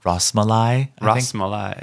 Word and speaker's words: Rasmalai: Rasmalai. – Yeah. – That's how you pronounce it.